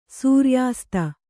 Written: ಸೂರ್ಯಾಸ್ತ

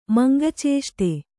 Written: ಮಂಗಚೇಷ್ಟೆ

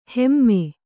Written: ಹೈಮ

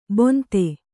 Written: ಬೊಂತೆ

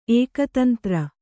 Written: ಏಕತಂತ್ರಿ

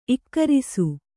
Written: ಇಕ್ಕರಿಸು

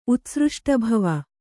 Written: ಉತ್ಸೃಷ್ಟಭವೆ

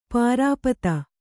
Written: ಪರಿಪೀತ

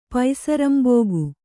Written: ಪೈಸರಂಬೋಗು

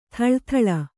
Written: ಥಳಥಳ

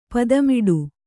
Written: ಪದ್ಮಾಟ

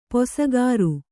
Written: ಪೊಸಗಾರು

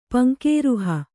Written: ಪಂಕೇರುಹ

